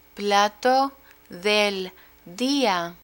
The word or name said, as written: plato del dia